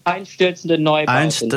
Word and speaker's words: Einstürzende Neubauten: Einstürzende Neubauten, is that how you say it? – Yeah, Einstürzende Neubauten.